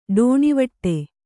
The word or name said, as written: ಡೋಣಿವಟ್ಟೆ